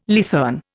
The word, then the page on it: leathern: Indian